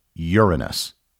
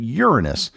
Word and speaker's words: Uranus: If you poll astronomers, they will almost universally tell you that the name of the seventh planet is Uranus. Likewise, if you check various dictionaries and pronunciation guides, they will also confirm that Uranus is how the planet is pronounced.